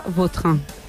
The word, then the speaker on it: Vautrin: Es que a mí me suena mejor Vautrin. Es como... – Da más fuerza, ¿no? – Más puede repetir la pronunciación. – Elegante. – La original francesa, Vautrin. – Ahí pega un Vautrin.